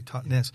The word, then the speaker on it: Totnes: Totnes, Totnes, as you say it properly, Totnes.